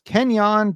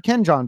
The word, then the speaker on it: Kenjon: Kenyon Barner. I've also heard Kenjon Barner. I don't think Kenjon Barner is correct, but Kenyon Barner, he is the 25th string running back for the New England Patriots, who love to run a two-back system.